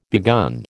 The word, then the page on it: begun: American English